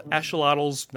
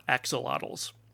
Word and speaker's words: axolotl: Now, why am I pronouncing that word like that? It's a AX in the front of the word, and that's pronounced like an SH in the Aztec language. So they're called axolotls, not axolotls.